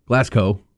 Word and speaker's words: Glasgow: It's not Glasgow. Glasgow. Come on, man.